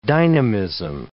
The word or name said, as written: dynamism